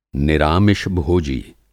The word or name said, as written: निरामिष-भोजी